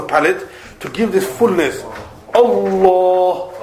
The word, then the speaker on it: Allah: Allah, Allah. – So the tongue, the back is rising towards the palate and giving that fullness in the sound while it's being pronounced, while it's coming out.